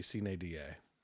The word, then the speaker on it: sine die: No, because the I's are pronounced E's and the E's are pronounced E, so it would be Sine Die.